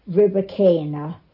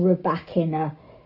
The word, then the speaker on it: Rybakina: Well, the women's was won by Elena Rybakina, who won, or Rybakina. Depending on who you listen to, it's a different pronunciation.